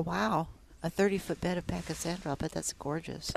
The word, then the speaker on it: Pachysandra: We've had Pachysandra, am I saying that right? – Yes, okay.